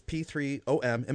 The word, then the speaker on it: P3OM: So your product, which is P3OM, am I saying that right? – Yep. – Okay. I didn't know if you maybe pronounced the ohm or something.